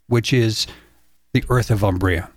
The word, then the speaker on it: Umbria: Umbria it is.